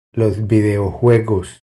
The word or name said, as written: Los videojuegos